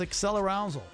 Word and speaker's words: accelerousal: I think I'm saying that right. Accelerousal. – It is. It's accelerousal.